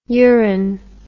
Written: urin

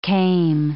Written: came

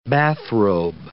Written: bathrobe

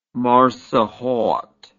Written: marsokhod

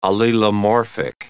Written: allelomorphic

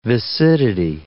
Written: viscidity

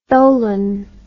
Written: tholin

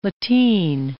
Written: lateen

